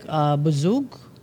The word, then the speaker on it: bouzouk: You have the electric bouzouk. How do you pronounce that? Bouzouk. – Yep.